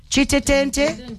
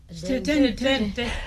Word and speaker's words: Chitetente: So this one, did I pronounce it correctly? Chitetente. Chitetente, Chitetente.